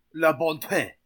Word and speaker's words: La Bonté: La Bonté. I just said it wrong, for sure. – La Bonté.